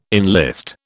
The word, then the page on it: enlist: British English